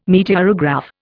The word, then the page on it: meteorograph: Indian